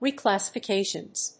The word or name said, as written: reclassifications